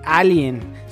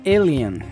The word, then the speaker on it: alien: Saludos a Joel, que también nos corrige nuestro inglés, como la semana pasada que eh, nos dijo, no se dice alien, se dice alien.